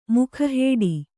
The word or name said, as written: ಮುಖಹೇಡಿ